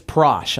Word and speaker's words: Prosh: Actually, I think it's Prosh. I think it rhymes with squash.